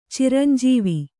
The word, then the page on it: ಚಿರಂಜೀವ: ciranjīva